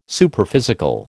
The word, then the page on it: Superphysical: American English